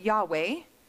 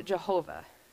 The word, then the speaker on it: Yahweh: We typically pronounce it Yahweh. Some other people pronounce it Jehovah.